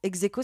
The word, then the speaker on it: Hexeco: Vous avez créé Execo, Execo, ça s'écrit H-E-X-E-C-O. Execo,